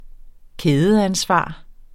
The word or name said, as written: kædeansvar